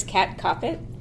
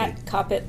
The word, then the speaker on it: Kat Koppett: Kat Koppett, like stop it.